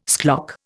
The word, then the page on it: schlock: Indian